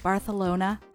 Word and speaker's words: Barthelona: It's called Barthelona. Released as a single. It is spelled Barthelona. I am not being pretentious. They phonetically spell out the Catalan pronunciation of Barcelona.